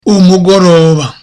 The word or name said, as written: umugoroba